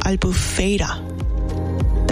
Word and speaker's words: Albufeira: Albufeira.